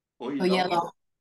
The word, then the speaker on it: Oyelowo: David Oyelowo.